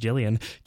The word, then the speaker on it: Gillian: Gillian, pronounced Jillian, Killian, pronounced Killian, Angie, Sarah Angelica, Anne, and Scotty.